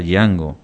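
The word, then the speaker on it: Dyango: Django se escribe Django y se pronuncia Django, y es Corazón Mágico la que tenemos ahí, la que le gusta acá a la señora Carmen, ¿verdad?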